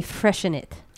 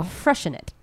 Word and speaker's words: Freixenet: I did not say it right. I think it's actually supposed to be freshen it. – Oh. – Freshen it. But it says, I mean, obviously it's like F-R-E-I-X-E-N-E-T.